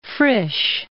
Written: Frisch

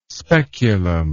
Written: speculum

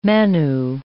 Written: Manu

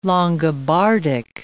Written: Longobardic

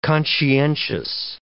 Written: conscientious

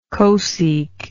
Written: cosec

